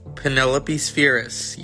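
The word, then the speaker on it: Penelope Spheeris: I want to make sure I'm saying her name right. Because yeah. – Penelope Spheris. – Penelope Spheris, yes.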